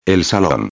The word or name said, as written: el salon